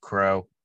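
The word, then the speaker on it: Crowe: That's pronounced Crow. Only reason I know that is because he's from the Nats.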